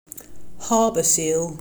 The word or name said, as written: Harbour seal